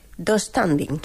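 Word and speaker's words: döstädning: Yo lo pronuncio do standing. – Sí, así. Tengo esa silla del IKEA, sí, se pronuncia así.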